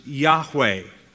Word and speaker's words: Yahweh: And it is perhaps and most probably pronounced Yahweh.